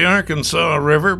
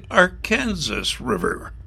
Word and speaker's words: Arkansas: In Arkansas, they pronounce it the Arkansas River, but in Wichita, Kansas, in the state of Kansas, it's the Arkansas River.